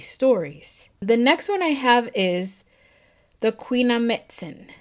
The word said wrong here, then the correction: Quinametzin: Why did I say it like that? the Queen Queenamitzen. Now, the Quinametzin were a race of giants that lived in Mexico.